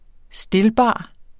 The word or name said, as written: stilbar